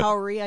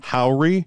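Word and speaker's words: Howery: Howry, I think. – Howry? – Yeah. – It's the L at the end of his first name colliding with the R.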